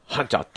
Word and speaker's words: 班長: その班の一番偉い人を班長って言うんですけども、英語では班長ってのはリーダーっていう意味で、その、俺は班長になりたいんだみたいなことを言うのは、そのまま班長っていう英語を使うんですね。リーダーって意味ですけども、彼は班長だぜとか言うんですね。 He's a 班長って言うんですよ。班長って言うんですけど、本庁に近い発音で発音しますけども、まあ、そういう言葉が英語に入ってきたと、日本語から。